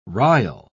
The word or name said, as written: rile